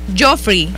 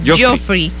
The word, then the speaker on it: Geoffrey: Geoffrey, ajá, así se pronuncia. Geoffrey.